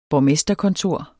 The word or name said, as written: borgmesterkontor